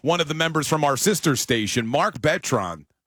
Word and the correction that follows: Bertrand: Bertrand. – There you go. – Yeah, it's Bertrand.